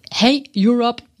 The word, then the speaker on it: Hey Europe: Es ist das Hey Europe Jugend Europa Festival. Habe ich das jetzt richtig gesagt? – Ich bin Ihnen dankbar, dass Sie es ausgesprochen haben. Ja, das ist komplett richtig.